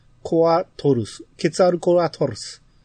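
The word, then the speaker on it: ケツァルコアトルス: でも、このちっちゃい泡は普通に発音していい泡やと思うんですよ。僕がよく言う。 – はいはい。 – 発音していい泡と、発音しない泡とある、あると思うんですけど、これは、ケツアでいいと思うんですよ。ケツアルコアトルス。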